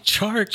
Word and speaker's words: Chark: You dropped Cortland Sutton and picked up DJ Clark Jr. Nope. You have to say it right, man. – DJ Clark. Do. – You have to say DJ Chark.